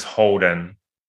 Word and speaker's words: Holden: Yeah, it's so my first name is Holden. It's Holden.